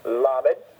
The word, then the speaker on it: Lamed: Lamed, two-syllable word, accent on the first syllable.